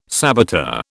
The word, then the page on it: saboteur: British English